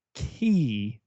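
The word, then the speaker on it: Caye: But Ambergris Key, Matt, you say it like it's a key, even though it may say K. They have... – That wouldn't rhyme with vacay.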